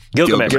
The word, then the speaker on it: Gilgamesh: I was like, man, I like that he didn't have to like him and the actor who played, what's his name, Gargamesh? Is that it? Is that how you say it? – Gilgamesh.